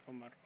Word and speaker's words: Omar: My name is Omar. O-M-A-R. – Oh, Marco? – Yes. – How you spell it? – O-M-A-R. – O-M-A-R.